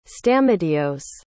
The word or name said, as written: stamatios